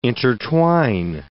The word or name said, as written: intertwine